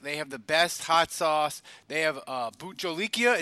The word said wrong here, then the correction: Jolokia: Is that right, Andrew? Did I say it right? – Jolokia.